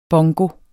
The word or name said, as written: bongo